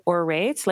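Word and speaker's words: Aurate: You pronounce it Orate, like an orator or storyteller, because we feel like we tell a story with the brand.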